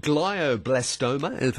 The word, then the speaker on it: glioblastoma: Today we're talking about, now, is it glioblastoma? Have I pronounced it right? – You have pronounced it absolutely correctly,